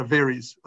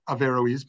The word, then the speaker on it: Averroes: Some people pronounce it Averroes, like if they're speaking fastly, but some people say Averroes, but they pronounce the O.